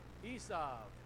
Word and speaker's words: Esau: Now the way it's pronounced is it's Esau with a V sound. The reason the V sound is there because it's related to grass in the field.